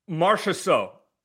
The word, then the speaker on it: Marchessault: Marchessault.